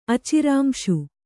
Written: ಅಚಿರಾಂಶು

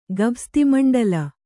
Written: ಗಭಸ್ತಿಮಂಡಲ

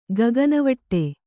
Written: ಗಗನವಟ್ಟೆ

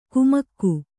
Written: ಕುಮಕ್ಕು

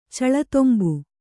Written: ಚಳತೊಂಬು

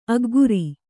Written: ಅಗ್ಗುರಿ

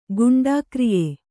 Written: ಗುಂಡಾಕ್ರಿಯೆ

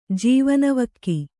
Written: ಜೀವನವಕ್ಕಿ

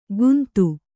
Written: ಗುಂತು

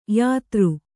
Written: ಯೋತ್ರ

